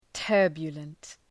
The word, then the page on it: turbulent: ‘tɜ:rbjələnt